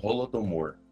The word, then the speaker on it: Holodomor: Basicamente, o trecho aí que deu mais treta foi a menção lá é, Holomodor, acho que eu pronunciei certo? É isso mesmo? É Holodomor.